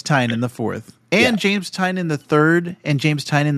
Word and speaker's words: Tynion: I did find out, I was just doing a little bit of research, it is pronounced Tynan. James Tynan the 4th. – So you pronounced it wrong, like, 3 times. – That's how I've been pronouncing it in my head, because that's how it's spelled, is Tynion. Yeah. But it's Tynan.